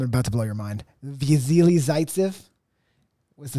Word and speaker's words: Vasily Zaitsev: Vasily Zaitsev. – Job. – Thanks, man. It's a hard name. – It is a hard name. I couldn't pronounce it. – Vasily Zaitsev.